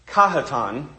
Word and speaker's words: Cajetan: Cajetan, Cajetan, however you want to pronounce it, I looked this up. Cajetan is the official pronunciation.